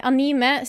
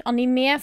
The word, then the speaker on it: anime: Men i dag skal jeg ta opp en eh, anime slash anime, for de som syns at det skal uttales sånn. Anime.